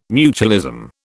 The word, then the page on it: mutualism: British English